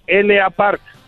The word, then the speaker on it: L.A. Park: se pronuncia como la parca. LA Park.